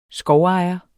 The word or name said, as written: skovejer